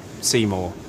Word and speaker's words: Seymour: Seymour. – Can you spell that, please? – S-E-Y-M-O-U-R.